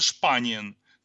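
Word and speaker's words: Spanien: Вот, если, например, мы не переходим на то название, которое есть, ну, например, у той же Испании, да, вот эта страна по-испански будет «Испана», по-немецки это «Шпаниен».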